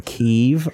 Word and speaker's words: Kyiv: I didn't know it was fucking Kyiv. I'd been calling it Kiev my whole life.